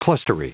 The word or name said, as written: clustery